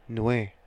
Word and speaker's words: Nweeia: No, Martin N-W-E-E-I-A. I don't know how to pronounce that last name. W-N-E-E-I-A? – N-W-E-E-I-A. – N-W-E-I-A? No, it's Nui.